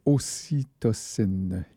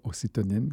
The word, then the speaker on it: ocytocine: Ocytocine, et non pas ocytonine, comme j'ai fait une erreur de prononciation l'autre jour.